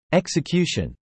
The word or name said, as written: execution